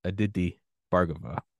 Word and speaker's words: Aditi Bhargava: It's so tongue to palate. So Dr. Aditi Bargava.